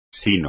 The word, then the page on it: sino: espagnol